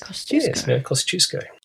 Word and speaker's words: Kosciuszko: That's the Polish pronunciation for it. That's excellent. – Kosciuszko.